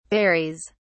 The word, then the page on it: varies: British English